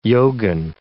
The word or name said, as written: yogin